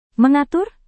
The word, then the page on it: mengatur: Indonesian